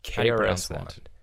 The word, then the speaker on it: KRS-One: KRS-One. How do you pronounce that? Is it just like that? You got to say the letters.